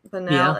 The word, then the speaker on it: banal: She was unafraid to call out the, how do you say this word? Banal? Is that how you say it? Banal. Yeah. Okay, I didn't know.